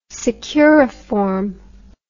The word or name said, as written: securiform